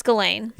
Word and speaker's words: Ghislaine: I think the H is silent. – Yeah, it's like Ghislaine. I think it's Ghislaine.